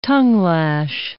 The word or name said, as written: tongue-lash